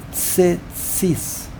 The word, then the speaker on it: tzitzis: Many people have the custom that they, if you have a chiruk that does not have a yud, you pronounce the chiruk like a shva, almost like a shva. That's why many people, they say tzitzis.